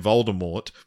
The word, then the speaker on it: Voldemort: Did you happen to choose the name Voldemort, which is actually supposed to be pronounced with a silent T?